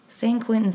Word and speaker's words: San Quentin: Am I saying that right? San Quentin seems right.